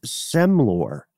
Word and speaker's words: semlor: I've heard some version of most of these on this list, but I am unfamiliar with semlor. Am I even saying that correctly? Semlor? – I think you are.